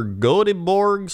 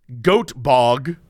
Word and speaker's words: Göteborg: So, the pronunciation, Göteborg, yes? That's what it's spelled? – Yeah. – And I guess it's also G-O-T-H, Gothenburg? Gothenburg? – Gothenburg. – But according to YouTube, according to Google, the pronunciation in America is goat bog.